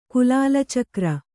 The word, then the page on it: ಕುಲಾಲಚಕ್ರ: kulāla cakra